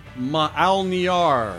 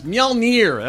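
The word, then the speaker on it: Mjölnir: It's Mjolnir. That was A, Mjolnir.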